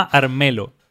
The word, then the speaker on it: Armello: Hay un juego que se llama Armelo. – ¿Ya? – Se escribe Armello. Armelo con doble L.